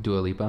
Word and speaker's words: Dua Lipa: Dua Lipa? Is that how you say it? – Dua Lipa.